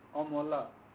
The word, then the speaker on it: Amala: A-M-A-L-A. – Pronunciation? – Omola.